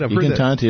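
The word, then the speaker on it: I Cantanti: E Cantante is right. E Cantante. It's Italian for the singers.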